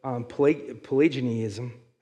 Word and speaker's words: Pelagianism: It's called Pelagianism. I'm saying, adding an extra syllable in there.